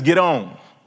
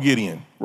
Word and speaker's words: Gideon: Real pronunciation of his name is get on. Get on. I'm not lying. Look it up in your concordance. Hebrew is get on. But we call him Gideon, right?